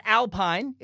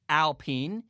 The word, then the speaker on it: Alpine: Uh, it's not Alpine. It's spelled like Alpine. If you're from America, you're like, oh, the Alpine F1 team. No, no, no, no, no. I watched the video. They clarified many times it's pronounced Alpine.